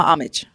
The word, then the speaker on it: homage: The H is silent, right? – It's hama homage.